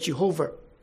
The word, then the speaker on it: Jehovah: But God is identified by his personal name, the Yod-Heh-Vav-Heh, Y-H-W-H, pronounced sometimes as Yahweh or sometimes as Jehovah.